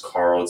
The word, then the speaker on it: Carl: My Twitter handle is Carl Za, just my name. Carl is spelled with a C, C-A-R-L, and my last name, Z-H-A.